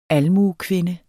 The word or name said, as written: almuekvinde